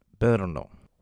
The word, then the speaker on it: Brno: That ancient city in Czech is pronounced Brno.